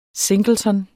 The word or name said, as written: singleton